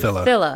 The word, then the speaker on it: Filla: Did I pronounce it correctly? I think I'm not pronouncing Filla correctly. – Filla. – Mm-hmm. Okay, like a killer.